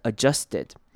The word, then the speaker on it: adjusted: 好，这边要注意的是 adjusted 呢，它的念法比较特别，它的开头的 A 是轻音 ad。好，那注意到这个 D 呢，我们可以稍微省略，然后念成 adjusted，你有没有发现并没有念出 d 的声音出来 adjusted。